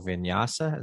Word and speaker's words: vinyasa: But you're trained in active and slow flow vinyasa.